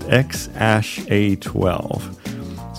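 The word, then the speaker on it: X Æ A-12: They say it's pronounced X Ash A12.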